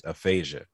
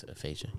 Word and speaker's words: aphasia: So, yeah, he was diagnosed with I'm really gonna probably butcher this, but I think the way you say it is aphasia. – Yeah, it is. I think it's aphasia.